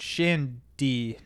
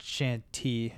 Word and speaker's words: Shandy: It's Shandy, not Shanty. – D as in Dalton. – D as in Dalton. T as in Dominion.